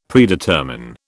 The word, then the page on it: Predetermine: British English